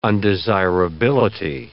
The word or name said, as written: undesirability